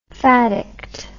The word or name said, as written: faddiction